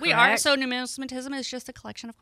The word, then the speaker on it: numismatism: And one of the things too is that I mispronounce this, but numismaticism. – Numismatism. – Thank you. Numismatism.